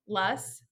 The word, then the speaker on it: loess: Um, and just a trick for you loess rhymes with bus.